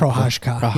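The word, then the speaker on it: Procházka: Yuri Prohash. How do you say his last name? – Prohashka. Yeah, you're right.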